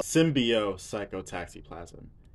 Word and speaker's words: Symbiopsychotaxiplasm: It's Symbo Psychotaxiplasm. Close. Symbio. Symbiopsychotaxiplasm. Yes.